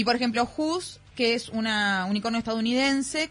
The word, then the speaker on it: Houzz: Who's. Se escribe H-O-U-Z-Z. Who's.